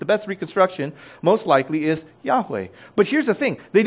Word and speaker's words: Yahweh: And so our best reconstruction of how to pronounce YHWH is Yahweh. It's Yahweh.